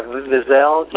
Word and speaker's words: Wiesel: And Eli Wiesel? – Yeah, Wiesel.